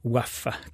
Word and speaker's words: WAF: J'ai nommé WAF, comme ça se prononce, ou plutôt d'ailleurs comme ça s'aboie.